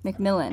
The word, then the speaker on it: McMillan: McMillan.